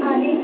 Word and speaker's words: Audrey: Audrey. – Audrey? How do you spell it? – A-U-D-R-E-Y.